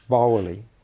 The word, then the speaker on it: bawili: So there's a word but, which is written B-A-W-I-L-I, sing. Now, because you've got a W after it, that's actually pronounced Bowley with a bit of an A sound.